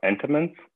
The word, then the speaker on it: Entenmann's: I think you're talking about Entenmann's.